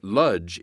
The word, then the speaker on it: Łódź: The Łódź ghetto, pronounced Ludge in Yiddish, was one of hundreds of Nazi ghettos across Europe used to separate Jews from the rest of the population.